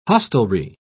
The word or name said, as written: hostelry